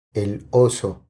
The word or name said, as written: El oso